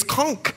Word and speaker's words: conch: It's conch. C-O-N-C-H. Someone asked me the other day, what is conch chowder? And I said, first of all, you're not from the Bahamas. It's conch chowder. I know it's spelled C-O-N-C-H, but it's conch chowder.